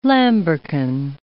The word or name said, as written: lambrequin